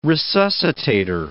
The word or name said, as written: resuscitator